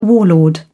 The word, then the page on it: warlord: British English